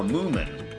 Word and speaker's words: Moomin: It almost sounds like Moomin, like you're mispronouncing women from Peter Cook in The Princess Bride. But this is not a mispronunciation, guys. You guys say the moo, like you're saying a cow, Moomin.